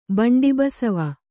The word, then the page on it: ಬಂಡಿಬಸವ: baṇḍI basava; kannada